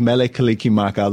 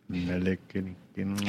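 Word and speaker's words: Mele Kalikimaka: The Hawaiian language has no sound for R or S, and every syllable has to end with a vowel sound. So Merry becomes Mele, and Christmas becomes Kaliki Maka.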